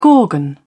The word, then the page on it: gorgon: British English